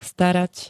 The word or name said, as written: starat